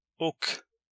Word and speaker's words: och: Och. And. Och. Now, even though this is pronounced och, I believe when you use it in a sentence, you only say the O.